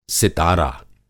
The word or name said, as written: सितारा